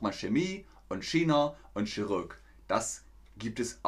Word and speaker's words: China: Wörter mit ch werden meist mit K ausgesprochen, wie in Bayern auch, zum Beispiel nicht Chemie, sondern Chemie und nicht China, sondern China.